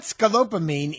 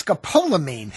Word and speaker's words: scopolamine: Well, first of all, it's not scopolamine, it's scopolamine.